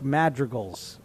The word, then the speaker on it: Madrigal: By the way, why can't Michael Kay pronounce Nick Madrigal's uh, name? How would you say Nick Madrigal's last name? madrigal, right? Is that how you guys would say it? That's how I would say it. Madrigal, madrigal from Michael Kay.